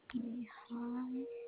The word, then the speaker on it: Lehigh: It's on the corner of Lehigh and 15th Street. – Lehigh. And Lee is spelled L-E-E, correct? – Lehigh, L-E-H.